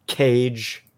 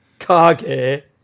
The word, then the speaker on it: Kage: Did you find on those 150 in ones, did you find anything called Cage? – Kage. Well, yeah, that's it. That's how you pronounce it. It's Shadow in Japanese.